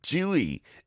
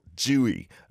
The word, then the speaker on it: Tzuyu: I always get confused with the English T-Z-U-Y-U. Give us your tips on how to pronounce that better. Thank you very much. Jui is the pronunciation in Hangul. Jui. My producer Minnie has just written it down. That is no way near the romanization, right? Zuyu. Interesting. Jui.